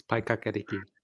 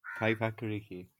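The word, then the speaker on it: Paekākāriki: It's Paikakariki. – Paikakariki.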